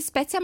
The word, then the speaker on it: Spetia: Allora, bisogna sapere che Simone Spezia, si pronuncia Simone Spezia, ma si scrive Spetia con la I, e quindi è Simone Spetia.